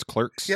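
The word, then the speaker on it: Klerks: Yes, it's Clerks. – Yep, like the movie, but with a K.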